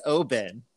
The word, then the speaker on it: Oban: Is it Oban or Oban? – Okay, so it is Oban.